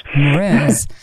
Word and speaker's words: Mraz: Nick Kershaw en Jason Mraz. Spreek je dat zo uit? – Mraz.